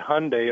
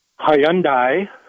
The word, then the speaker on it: Hyundai: And thank you for pronouncing it Hyundai. It rhymes with Sunday. – Well, how do some people pronounce it? – Hyundai, I've heard.